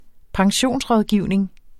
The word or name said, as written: pensionsrådgivning